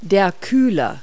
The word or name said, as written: radiator